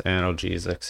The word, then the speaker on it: analgesics: Yeah, and that acronym was, I just looked it up, it's movement, exercise, analgesics, and treatment. Does that sound right? – Yes, analgesics.